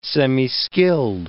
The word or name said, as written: semiskilled